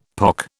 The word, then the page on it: pock: British English